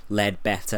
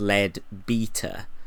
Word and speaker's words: Leadbeater: I think I've been pronouncing her name wrong. It is not Ledbetter, as in Huddie Ledbetter, aka Leadbelly. It is Leadbeater.